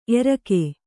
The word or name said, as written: ಏರಿಕ್ಕು